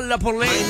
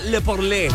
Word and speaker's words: La Porlé: Se juntó con The King DJ para hacer esto que se llama La Porlé. No se pronuncia Le Porlé. Así como francés. Le Porlé.